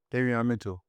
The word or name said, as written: davion MITCHELL